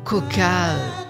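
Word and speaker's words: quokka: Donc maintenant, je vous propose d'écouter une autre histoire qui s'intitule « Le coca tueur ». Alors, coca, ça s'écrit Q-O-2-K-A, et c'est pas un verre de coca, hein, c'est un petit animal, vous allez voir, voilà, qui peut se montrer si agressif.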